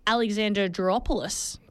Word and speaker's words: Alexandroupolis: Well, you would have been upset yesterday if you were over in the Greek city of Alexandroupolis.